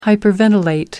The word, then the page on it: hyperventilate: British English